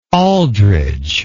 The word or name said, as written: aldridge